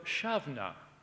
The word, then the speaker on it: Samhain: But the origins of Halloween date back to the ancient Celtic festival of Shavna. It's spelt Samhain. How you get Shavna from Samhain, only the Celts know. There's no V, apparently, in the Celtic language.